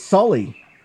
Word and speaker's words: Soli: Sully. S-O-L-I, as I understand he prefers it pronounced.